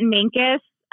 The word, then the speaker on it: Mancos: And is it Mancos, Colorado? How do you say that? – We say it Mancos, but there are a lot of pronunciations for things around here.